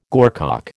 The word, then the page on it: gorcock: American English